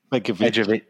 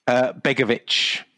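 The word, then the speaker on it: Begović: Begovic. – Off to AC Milan. He was one of our targets. Begovic.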